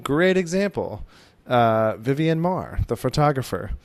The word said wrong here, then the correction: Vivian Maier: Yeah, Vivian Maier is great. – Did I say that right? Okay.